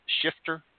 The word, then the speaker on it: Schifter: Cassia Schifter, is it pronounced Schifter? Yes, that's correct. Okay. Uh, Cassia Schifter, she is the Director of Restaurant Awards, also Associate Tasting Coordinator with Wine Spectator Magazine.